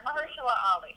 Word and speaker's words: Mahershala Ali: So for 6 Degrees, we did Mahershala Ali. I still don't think I'm saying his name right, but We're gonna. – There you are, Mahershala Ali.